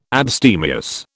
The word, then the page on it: abstemious: British English